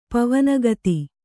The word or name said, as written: ಪವನಗತಿ